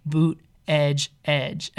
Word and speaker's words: Buttigieg: He's, like, trying to teach them how to say it, and they're like, he's like, repeat after me, boot, edge, edge, and they're like, blueberry. And but yeah, Pete Buttigieg,